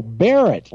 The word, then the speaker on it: Barrett: Barron in North Carolina. Have we got you on the air and got your name right here? – It's Barrett. How you doing? – Oh, Barrett, like the 50 caliber.